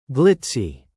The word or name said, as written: glitzy